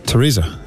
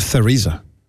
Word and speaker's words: Teresa: Teresa. It's not Theresa. – Her name is spelled T-E-R-E-S-A. So she therefore doesn't have a silent letter. – Is there no H in Teresa? – There's no H in Teresa.